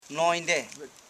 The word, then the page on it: nonimde: non.i.mde; Koro